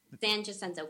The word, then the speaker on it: San Jacinto: San Jacinto College. – San Jacinto College. – By the time I get it, we'll be over. – It'll be done. Yeah, I know, right? Yeah. San Jacinto.